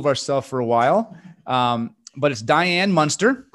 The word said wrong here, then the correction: Mynster: Um, and I hope I said that correctly. It's Mynster. Mynster. See, I did not. Diane Mynster.